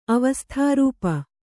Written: ಅವಸ್ಥಾರೂಪ

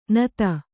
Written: ನತಿ

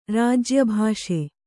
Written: ರಾಜ್ಯಭಾಷೆ

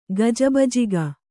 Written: ಗಜಬಜಿಕೆ

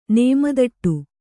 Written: ನೇಮದಟ್ಟು

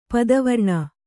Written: ಪಿತ್ತಾವರಣ

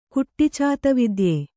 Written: ಕುಟ್ಟಿಚಾತವಿದ್ಯೆ

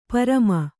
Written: ಪ್ರೇಮಿ

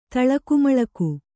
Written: ತಳಕುಮಳಕು

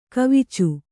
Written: ಕವಿಚು

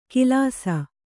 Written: ಕಿಲಾಸ

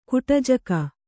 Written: ಕುಟಜಕ

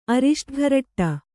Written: ಅರಿಷ್ಟಘರಟ್ಟ

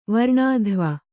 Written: ವರ್ಣಾಧ್ವ

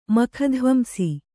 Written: ಮಖಧ್ವಂಸಿ

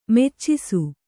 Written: ಮೋಚಿಸು

